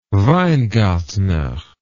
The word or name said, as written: weingartner